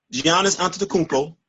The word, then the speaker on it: Antetokounmpo: So you're going up against a Giannis Antetokounmpo.